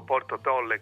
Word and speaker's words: Porto Tolle: Porto Tolle, che poi è quasi impronunciabile per uno che non è polesano, loro dicono Porto Tolle, non si capisce. Ma io lo dico Porto Tolle, come direbbe il nostro vocabolario.